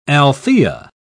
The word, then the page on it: althaea: British English